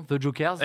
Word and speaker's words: The Jokers: J'ai toujours un doute sur la prononciation, The Jokers. – Exactement. Ah, ah, un petit mélange de, français et d'anglais.